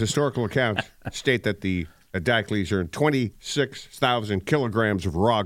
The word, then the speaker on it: Diocles: How you say his name again? – Just Diocles is good enough. – Diocles. Okay.